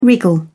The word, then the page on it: wriggle: British English